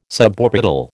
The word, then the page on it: suborbital: American English